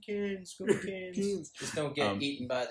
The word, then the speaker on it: Cairns: Have only ever been to Cairns, is that how you pronounce it? – We are about to move to Cairns. What? – Cairns.